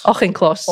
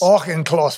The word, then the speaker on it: Auchincloss: Auchincloss. – Auchincloss,